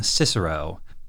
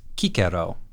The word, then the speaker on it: Cicero: In English, we would call him Cicero, but in the Japanese, it's Kikero, which is a lot closer to the classical Latin pronunciation for this guy's name.